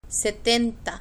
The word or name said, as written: setenta